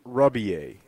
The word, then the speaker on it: Rebillet: Rubier, and it's spelled Rebillet, like the French.